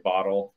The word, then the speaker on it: Bottell: Bottell.